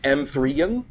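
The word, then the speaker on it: M3GAN: Also, I believe we're mispronouncing the title. Per the posters, it's M3N. – Oh, sorry. – Right? Right. – Yes. – Soft G.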